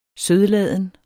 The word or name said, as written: sødladen